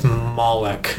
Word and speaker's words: Moloch: I was like, oh, it's Moloch. – With a hard K. – Yeah. – The hard C.